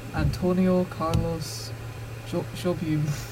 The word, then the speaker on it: Antonio Carlos Jobim: We're gonna see you out with Brazil by Antonio Carlos Jobim.